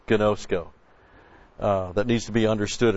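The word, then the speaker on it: ginosko (γινώσκω): G-I-N, long O, S-K, long O, ginosko.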